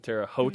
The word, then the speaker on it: Terre Haute: You still have regionals and then the NCAA championships in Terre Haute.